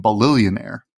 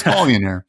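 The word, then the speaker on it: Ballionaire: It's, but I keep on saying Balillionaire, but that's not how it's spelled. So it's Balionaire.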